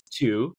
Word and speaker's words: Tou: But yeah, my name is Tu. It's spelled T-O-U, pronounced just like the number two.